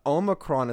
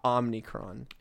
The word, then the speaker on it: Omicron: I've heard both, but I've heard that Omicron is correct, not Omnicron.